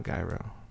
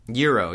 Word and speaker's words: gyro: Except Euro. Like, it's Euro. It's not Gyro. – Euro, yeah. It's Euro. You don't pronounce that G, people.